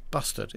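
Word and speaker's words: bustard: Bastard, sorry. Bastard is how you pronounce it.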